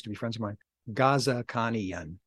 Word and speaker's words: Ghazikhanian: But they pronounce it Ghazikhanian to us, so.